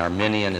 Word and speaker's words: Arminian: And maybe everybody knows that an Arminian is different than an Armenian. Remember, Armenia is a country. So say it correctly.